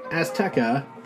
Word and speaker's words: Azteca: Uh, HBO Max Latin America will be taking The Dark Knight to the Aztec Mexico in a new project titled Batman Aztecia. I mean, Azteca Okay, I'm not pronouncing the Spanish title.